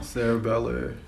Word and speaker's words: cerebellar: Cerebellar.